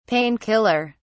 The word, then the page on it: pain killer: British English